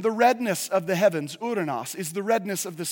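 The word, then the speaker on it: uranos (οὐρανός): That term is pronounced uranos.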